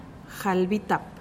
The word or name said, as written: jalbitap